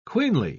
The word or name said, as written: queenly